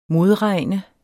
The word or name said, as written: modregne